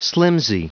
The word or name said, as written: slimpsy